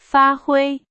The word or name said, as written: 发挥